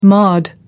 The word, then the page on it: mod: American English